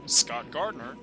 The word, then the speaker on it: Scott Gardner: You can find me, Scott Gardner, both on Twitter and Facebook. My name is spelled S-C-O-T-T. G-A-R-D-N-E-R.